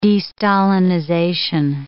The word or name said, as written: de-Stalinization